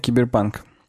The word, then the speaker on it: киберпанк: Напоминаю, киберпанк. Я серьезно с ударением на панке напоминаю.